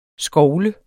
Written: SKOVLEDE

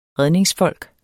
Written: REDNINGSFOLK

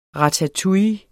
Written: ratatouille

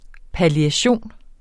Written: palliation

